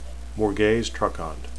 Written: morgase

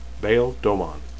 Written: domon

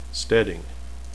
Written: stedding

